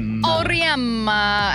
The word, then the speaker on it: Auriemma: Auriemma.